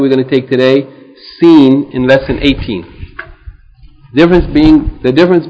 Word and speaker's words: Seen: Okay, also, before we read the lesson today, I want to just mention that the letter Seen is pronounced like the S in sun. The S in sun, S-U-N. but never like the S in rose. R-O-S-E.